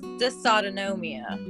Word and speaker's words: dysautonomia: but it's actually pronounced dysautonomia.